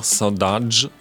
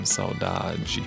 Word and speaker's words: Saudade: Man kann es auch Saudade aussprechen, das wäre jetzt die angolanische Aussprache davon. Aber in Brasilien heißt das eben Saudade.